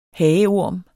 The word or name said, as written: hageorm